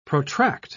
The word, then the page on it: protract: British English